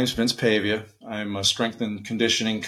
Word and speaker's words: Pavia: Am I saying that right? – Yeah, you're saying it right. Pavia, Pavia. It depends on what part of the country or world you're in. – I actually didn't know that's how you said it until we went to Masters Championship, because I always said Pavia. That's what I thought it was. So I got corrected. So Another common pronunciation.